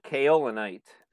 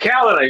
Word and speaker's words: kaolinite: Oh, the kaolinite. – Kaolinite, see, I'm like kaolinite.